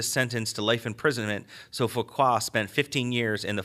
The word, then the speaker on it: Fouquet: So you also had Nicolas Fouquet. That's how you pronounce that, right? Fouquet. – Sure. – Fouquet. I think I'm okay with that one.